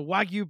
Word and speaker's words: wagyu: So Wagyu beef.